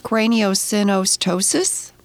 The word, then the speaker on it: craniosynostosis: You were born with, let me see if I can say this correctly, craniosynostosis? – That's pretty darn close.